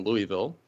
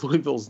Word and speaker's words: Louisville: To put it bluntly, yes, the proper way to say it is Louisville, and not the more common Louisville that so many people in this city continue to say.